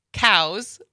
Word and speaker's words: COWS: And then we're going to talk to Laura Dresser, who is the Associate Director of COWS, which I think is pronounced COWS. I don't think it's C-O-W-S.